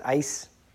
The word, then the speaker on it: eis: It's pronounced eis. It's E-I-S.